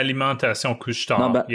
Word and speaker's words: Alimentation Couche-Tard: So I originally started writing preparation of Alimentation Couche-Tard. How did I say that, by the way? How was that? – It's not bad. Alimentation Couche-Tard. Yeah, there's like no D. Okay. You don't pronounce the D.